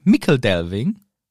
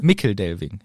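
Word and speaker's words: Michel Delving: Der im Englischen ausgesprochen wird Mickledelving. – Michelbinge? – Mickledelving, ja.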